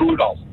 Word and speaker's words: Rudolph: It is Rudolph. You got to get that L in there.